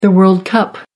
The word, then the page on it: world cup: American English